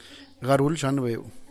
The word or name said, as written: garudxanbeu